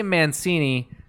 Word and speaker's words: Mancini: Well, say I say Mancini because there's a tradition of like, you know, there's Boom Boom Mancini, the boxer,